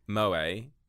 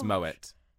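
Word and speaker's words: Moët: And I'm like, just say moed. We know it's incorrect. – Oh my god. It's moet.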